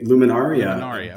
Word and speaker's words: Luminaria: Sure. We say Luminaria. But the experience is just overwhelming no matter how you pronounce it.